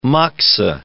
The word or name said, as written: moxa